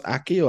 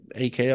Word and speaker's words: AKI: I didn't know whether it's pronounced Aki or AKI or I people call it Aki. I tend to call it AKI for some reason, but yeah, I guess they're interchangeable.